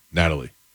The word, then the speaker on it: Nathalie: According to court documents in the Miami-Dade County, how would you say it? N-A-T-H-A-L-I-E. – Natalie. – That's Natalie? – Yep. – With an H in there? – Yep, it's silent.